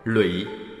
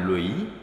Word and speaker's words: lũy: Huyền nghĩa: chúc là gửi, phó thác, lụy lẽ ra phải đọc là lũy, có nghĩa là chồng chất.